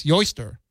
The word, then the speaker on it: Eostre: it goes back to the pagan goddess Yoster. Yoster. It's spelled like Easter. I think Yoster is the right way to say it. E-O-S-T-R-E.